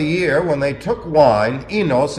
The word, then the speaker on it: oinos: O-I- N-O-S. The diphthong O-I is pronounced E, enos.